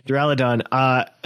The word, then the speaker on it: Duraludon: Duraludon. – I put the emphasis on the owl. – There we go. Duraludon.